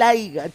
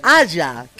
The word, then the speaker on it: haya: Más que el pacuso y lo que me acaba de decir, me lastimó el oído el aiga, chicos. Es haya, que haya olor.